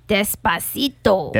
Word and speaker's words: Despacito: And now it's, how you say it? Despectio? – Despacito. – Despacito.